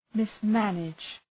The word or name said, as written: mismanage